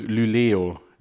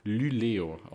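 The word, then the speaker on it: Luleå: Es wird Sverie und Lüleo. Lyleo ausgesprochen. Okay.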